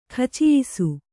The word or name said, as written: ಕಚಾಯಿಸು